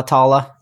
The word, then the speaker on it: Tala: Tala, Tala. – Is that how you say it? Yep, exactly how I would say it.